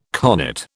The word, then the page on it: carnet: British English